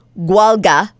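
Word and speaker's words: Gaeilge: No, I don't know the language, but I do know that it's pronounced Gwalga. And Gwalga. – It's not Gaelic, but it's kind of like that.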